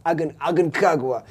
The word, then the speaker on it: Aconcagua: Aconcagua.